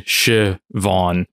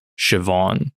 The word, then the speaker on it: Siobhan: That's another spelling for it is S H A V A W N. – Hmm. – It says English pronunciation Siobhan with the emphasis on Vaughn. So Siobhan.